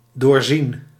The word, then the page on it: percevoir: /pɛʁ.sə.vwaʁ/; France